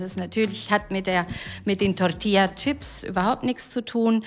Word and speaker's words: Tortilla: Es gibt Tortilla. So, Aussprache war schon mal in Ordnung. Muy bien, sehr gut.